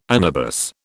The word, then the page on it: anabas: British English